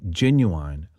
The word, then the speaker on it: Genuin: Z-E-L-E-N-K-A. It's on the Genuine label. That's how I'm saying it. It's spelled G-E-N-U-I-N. So maybe more Genuine label.